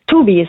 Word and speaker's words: Tubi: Tubi ist richtig, ist nicht Englisch.